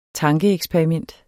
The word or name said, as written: tankeeksperiment